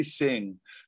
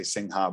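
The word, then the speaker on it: Singha: And Sing, I say Sing, not to be like an asshole or anything, but the HA is silent. Ooh. For anyone outside of Thailand, they put the HA in there because the word Sing, I'm pretty sure, also has a silent letter in Thai at the end of it. So they're saying true to the form, if that makes sense. So it's only Sing. Only foreigners say Sing Ha.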